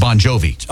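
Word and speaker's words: Bongiovi: It's Bon Jovi.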